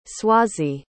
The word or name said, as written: Swazi